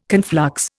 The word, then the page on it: conflux: Indian